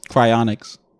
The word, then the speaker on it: cryonics: So listing at number 10, I think it's pronounced cryonics. – Cryonics.